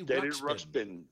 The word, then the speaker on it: Ruxpin: Give us a pronunciation there. – Teddy Ruxpin. – Ruxpin. Yeah. Okay.